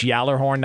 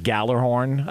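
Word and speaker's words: Gjallarhorn: I believe it's Yallerhorn, not Gallerhorn. I believe that's the proper pronunciation of the uh, uh, the device they use at Vikings games.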